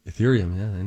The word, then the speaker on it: Ethereum: Ethereum, yeah. – Oh, is that how you say it? Ethereum? – Yeah.